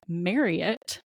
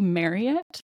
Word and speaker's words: Marriott: And on the first day, they said, the first thing we need to get out of the way is that you've been saying Marriott wrong your entire life. So it's actually Marriott, similar to Elliot, not Marriott.